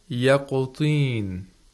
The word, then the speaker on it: يقطين: And the word يقطين, طين, طين, not طين. طين is incorrect. طين. The طا is pronounced with the lowest level of تفخيم.